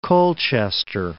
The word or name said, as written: Colchester